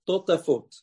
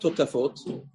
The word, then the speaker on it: totafot: Now here's the way to pronounce the letter tet. Totafot. Watch. I did it quickly. But it's not totafot, because the letter taf only comes from the edge of your tongue on and attaching to your palate.